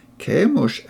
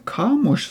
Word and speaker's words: Kemosh: Kemosh, eller Kamosh som noen oversetter det, er egentlig navnet på den guden som ble tilbedt i dette området.